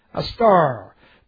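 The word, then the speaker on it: A star (ἀστήρ): Here's the Greek word. A-S-T, long E-R. A star.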